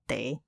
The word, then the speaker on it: teh: 就叫 teh，跟台语一模一样。